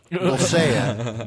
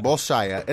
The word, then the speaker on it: Bolshaya: Bolshaya. It's Bolshaya Pass. – Yeah. – Oh, so duh. Bolshaya of course. – But yeah, so this zone actually... – That one wasn't even difficult. Just pronounce it phonetically.